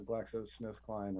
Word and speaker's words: GlaxoSmithKline: GlaxoSmithKline or Smith GlaxoCline or GlaxoSmithKline or whatever.